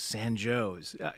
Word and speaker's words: San Jose: San Jose is San Joe's.